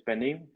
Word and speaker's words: pernil: So it's spelled P E R N I L. Um, in Spanish, it's penil, but in um, English, it would be pernil.